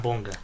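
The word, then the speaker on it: Bonga: Site, το τονίζουν στην πρώτη συλλαβή, άρα Μπονγκά.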